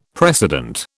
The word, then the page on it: precedent: British English